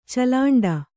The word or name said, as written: ಚಲಾಂಡ